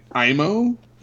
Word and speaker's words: Aimo: You had Tommy Korpela played Aimo, I think is how you pronounce his name.